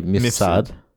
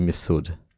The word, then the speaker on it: Mifsud: So, if I was Australian, I would say Mifsud. – Mifsud. – But if I was Maltese or properly pronouncing, it would be Mifsud.